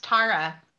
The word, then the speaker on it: Tara: Yeah, and my name is actually pronounced Tara.